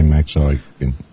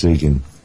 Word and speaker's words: Eigen: and they still say Max Eigen. – I've listened to so many different pronunciations, but I had to listen to quite a few to hear you say it yourself before I knew which way to pronounce it correctly. – Yeah, it's Egan. Mm-hmm. It's actually the original Irish spelling of Ian.